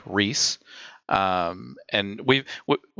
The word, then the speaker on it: riis: the word riis um r i i s in this spelling it shares a very shares the same pronunciation, I believe, as well as very similar spelling to the word Rhys in uh, ancient Nordic, which refers to like nature and gardeners.